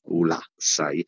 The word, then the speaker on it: Ulassai: A Ulassai ho passato una giornata incredibile con l'accento sulla prima A, Ulassai.